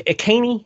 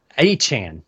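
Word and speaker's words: Achane: Is it Akeney? Is that how you pronounce his name correctly? Achan. – Yeah. I wasn't anywhere close.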